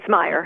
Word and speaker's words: Meyer: Pamela Mayer. Did I say that right, Pam? Is it Mayer or Meyer? – It's Meyer. – Meyer, I'm sorry.